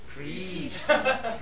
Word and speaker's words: Krishna: So in the word Krishna, it's actually the vowel is the R. It's not K-R-I. It's actually K-R. So it's the KR is exactly like the English word, like curd, or cur, like you cur.